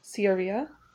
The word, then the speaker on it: Sharia: Oh, I looked up the pronunciation. It's Sharia. Anglicizing the word.